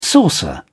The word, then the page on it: saucer: British English